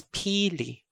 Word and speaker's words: pili: Again, the word is spelled P-I-L-I. Let's go over the easiest pronunciation, which is Pili. Pili. Okay. The first E is a long vowel followed by a normal E sound. No stop, not prolonged, just normal E.